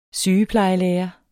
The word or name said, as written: sygeplejelærer